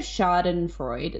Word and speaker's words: schadenfreude: And I think that the reason for that is because it's like a bit of schadenfreude.